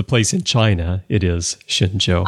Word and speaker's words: Shenzhou: It is Chinese, and I was close. It's the ZH is not zhe, but zhe, but it is Shenzhou.